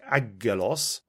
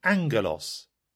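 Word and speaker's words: angelos (ἄγγελος): For instance, the double G in Greek had a special pronunciation. The word that looked like agelos was really angelos.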